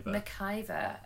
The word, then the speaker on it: McIver: I think it's Rose McCleaver. Cliver. She's the. – Oh, from Game of Thrones again. – No, she's in iZombie. – Oh, yeah. Rose McIver. – McIver, okay.